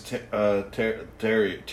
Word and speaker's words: Theriot: It says, Max Theriot, that's how it's pronounced.